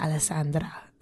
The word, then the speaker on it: Alessandra: Everybody pronounces my name wrong because there's no real right way to say my name fully unless you have like an Italian accent. You're like Alessandra. Like that's the only way.